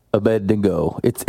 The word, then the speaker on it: Abednego: Shadrach, Meshach, and Abednego. – You don't have to say it like that. You can say Abednego.